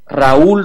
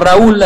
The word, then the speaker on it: Raoul: sale que está dirigida por Raúl Ruiz a la francesa, se escribe R-A-O-U-L, no Raúl así a la chilena, sino que un Raúl afrancesado,